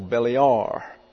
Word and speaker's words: Belial: And then you have E-L-I-A-R. And that looks like this. And the accent's on the last syllable, Belial.